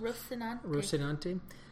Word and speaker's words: Rocinante: So next question, many people, including Miller and the crew of the Rocinante, that I always have to pronounce. – Rocinante.